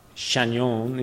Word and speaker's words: Chagnon: Another perhaps more grave example is from Napoleon Chagnon, or Chagnon, if you want to pronounce it like the French do.